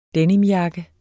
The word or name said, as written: denimjakke